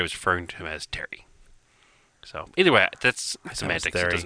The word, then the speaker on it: Thierry: I think you just pronounced it Terry. – I thought it was Thierry. – Yeah. I think it's the French spelling of Terry, pretty much.